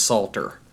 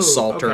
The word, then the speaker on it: Psalter: Because Psalter, S-A-L... – P? – Oh, it starts with a P. – P-S. – Oh, it starts with a P. – P-S-A-L-T-E-R. So it's, right, Psalms, the Psalter...